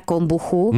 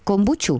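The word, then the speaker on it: kombucha: Než půjdeme k anketka, možná ještě se zeptáme na kombuchu, neboli kombuču. Vyslovuje ch nebo č.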